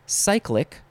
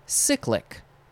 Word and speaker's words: cyclic: Next is, you could pronounce it two ways, cyclic or cyclic.